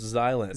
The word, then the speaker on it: Xilent: I think it might be pronounced Xylent. – Xylent. – I've heard from some people that it's pronounced Xylent. – Xylent. – Like the X is a Z sound.